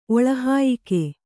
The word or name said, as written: ಒಳಹಾಯಿಕೆ